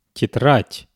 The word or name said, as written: tetrad